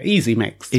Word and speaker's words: EasyMixt: I think I pronounced that right. It's EASYMIXT. – Yeah, Easy Mixed.